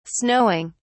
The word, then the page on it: snowing: British English